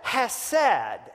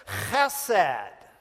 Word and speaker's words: chesed: Because we said hesed, and actually we've got to be good Hebrews here. And so what that means is it's a throaty hesed. It sounds like this, hesed. All right, you may have to cover your mouth, and that's okay if you do that, but that's how to pronounce it.